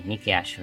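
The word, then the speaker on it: Nikki A.S.H.: Non parliamo del fatto che Nicky Ace, vabbè, Nicky Ash, perché Nicky Ace e H non mi piace pronunciarlo che bel push che stanno dando a questa prima.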